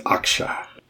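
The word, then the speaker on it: Akshay: Ashke Bhatia. Am I saying that correctly? – I think it's Aksha.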